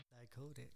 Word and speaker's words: Dicodec: Dicodec.